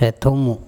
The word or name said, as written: Ethomo